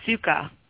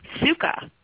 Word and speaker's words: Scuka: Suka. – What? – Suka. – And how do you spell it? – S-C-U-K-A. – S-E-U-K-A,